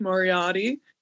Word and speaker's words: Moriarty: Today I'm joined with Josh Moriarty.